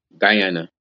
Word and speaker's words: Guyana: Am I saying that right? – Guyana. – Guyana. Sorry, yeah.